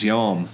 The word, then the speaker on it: Yarm: It's where sounds like JD pronouncing Yam or something. – Yeah, it's Yarm.